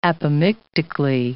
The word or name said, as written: apomictically